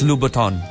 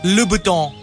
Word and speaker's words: Louboutin: Dapat walang N. – Louboutin. – Louboutin. – Don't do it. – Sige, tuloy mo yan. – Don't do it. – So it's like two syllables, Lub and Uton.